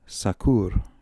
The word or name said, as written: Sakoor